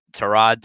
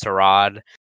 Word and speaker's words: Tyrod: now Tyrod Taylor. Um, basically, there was this whole like this thing that came out um, about how his name is actually pronounced Tyrod uh, instead of Tyrod, and I'll let uh, Bobby play that clip real quick.